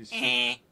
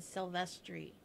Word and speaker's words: Silvestri: Sophie Silverstreet. How do you pronounce that? – Music by John Bon Jovi and Alan Silvestri.